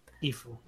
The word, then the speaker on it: Gifu: And there was also a local promotion, I think, in Gifu, is how you say it, prefecture? – Gifu, yeah.